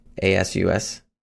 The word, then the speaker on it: Asus: Uh, um, that's Asus, right? A S U S, Asus. That's how you pronounce it.